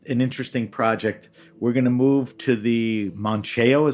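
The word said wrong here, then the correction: Mouchão: Is that how you pronounce it? – No, it's Muxiao. – Muxiao. – Muxiao. – The wire is in front of the U. Muxiao, M-O-U-C-H-A. – A with the tilde.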